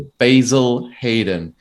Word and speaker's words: Basil Hayden: I'm going to pronounce it the American way, the Basil Hayden. It sounds very wrong saying it that way.